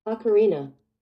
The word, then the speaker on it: Ocarina: Ocarina.